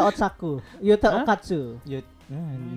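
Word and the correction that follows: Okkotsu: nah, nyebut namanya aja salah dia. – Mm -mm, Okotsu, Okotsu kan.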